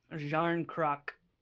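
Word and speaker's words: Järnkrok: Kale Jarnok? Jarnok. – Jarnkrok.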